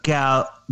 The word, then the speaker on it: Gal: G-A-L. Gal. And then her last name. – Oh, Gal Gadot.